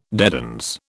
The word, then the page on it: dedans: British English